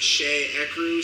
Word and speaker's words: Cheecru: I'm gonna start with a dude by the name of, I think it's pronounced Shay Ecru, C-H-E-E-C-R-U.